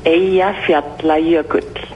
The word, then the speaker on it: Eyjafjallajökull: The pronunciation is Aya fiabla yurkel.